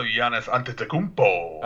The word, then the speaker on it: Giannis Antetokounmpo: My number three is also Giannis Antetokounmpo. – Oh, you said it right.